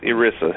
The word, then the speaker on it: ERISA: That's called ERISA, Employment Income Employment something. It's ERISA is how they pronounce it.